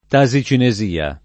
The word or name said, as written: tasicinesia